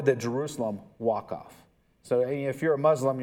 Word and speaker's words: Waqf: Waqf. I think I'm pronouncing that right. It's W-A-Q-F. So Waqf, that's how I'm saying it. And that's how I think that's supposed to be said.